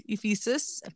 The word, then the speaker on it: Ephesus: Like Ephesus in Western Turkey.